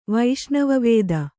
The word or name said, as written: ವೈಷ್ಣವವೇದ